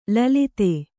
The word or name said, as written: ಲುಲಿತ